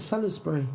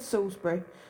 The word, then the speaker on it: Salisbury: I thought it's Salisbury. – No, it's spelt Salisbury, but it's said Salisbury.